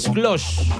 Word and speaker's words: Klose: Pero el más grande goleador hasta ahora es de Alemania, de Miroslav Klose. Imagino que estará bien pronunciado, ¿no? 16 goles. – No, sí, estuvo bien en los idiomas, ¿no?